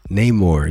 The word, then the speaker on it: Namor: How do you pronounce it ba? – Namor. It's Namor.